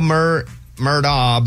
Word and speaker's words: Murdaugh: Well, and so it is spelled Murdaugh, but Amy told me, "Hey, idiot, it's Murdaugh."